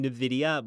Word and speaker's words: Nvidia: Navida, is that how you pronounce it? Navida? – Nvidia. You're almost saying Nvidia, but they don't have Nvidia actually, but like Nvidia. Nvidia. Something like that.